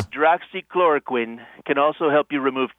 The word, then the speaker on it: hydroxychloroquine: It's for malaria, and it's the hydroxychloroquine.